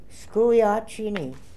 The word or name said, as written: Skoohiatchiny